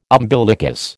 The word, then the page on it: umbilicus: American English